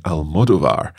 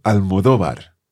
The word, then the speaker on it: Almodóvar: One thing to mention is the pronunciation of Pedro Almodóvar's second name. I think in English, very often we hear people saying Almodóvar, but the stress should be on the O of the second last syllable, Almodóvar.